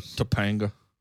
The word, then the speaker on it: Topanga: Patanga, is that how you say it? Patanga? – I've said her name wrong for 25 years. – If Boy Meets World, Danielle Fishel's the real name. I got a couple of posters. – I call her Topanga.